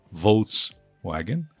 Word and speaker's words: Volkswagen: Volkswagen, na pronúncia americana da palavra, Volkswagen. Volkswagen seria o nome da empresa alemã pronunciado em inglês americano, Volkswagen. Eu sei que em alemão é outra história, mas aqui o foco é a língua inglesa.